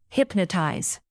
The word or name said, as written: hypnotize